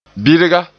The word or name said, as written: birga